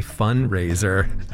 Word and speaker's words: fundraiser: It's a silent D. You say fundraiser.